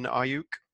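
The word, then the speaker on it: Aiyuk: So this one we're addressing the other need, the wide receiver, with Brandon Ayuk. I believe that's correctly pronounced. There's a little phonetic spelling on the websites that I found.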